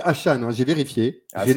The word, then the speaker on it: Achane: Il m'a dit, non, mais on dit Devon Achane. – Achane. – Donc, on dit Achane.